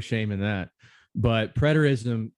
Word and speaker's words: preterism: Yes, preterism is how you'd say it.